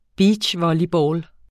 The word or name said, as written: beachvolleyball